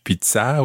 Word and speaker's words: pizza: And pizza in French, pizza with a stress on the A, pizza. – Pizza. – As opposed to in Italian, pizza.